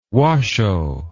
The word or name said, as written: washoe